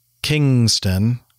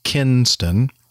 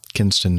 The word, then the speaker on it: Kinston: pronounced Kingston when the actual nav aid is Kinston, Kinston, North Carolina.